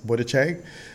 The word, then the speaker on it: Buttigieg: I'm also told that I announced Pete Buttigieg's name incorrectly. It is Buttigieg.